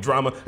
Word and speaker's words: Jrama: So if y'all seen any of the music videos I did in the past for the homie Drama, that's Drama with a J. Fucks with him. – What? – But what? – Drama with a J. J-R-A-M-A. – But you pronounce it drama. – Say, well, how would you say it? J-R-A-M-A.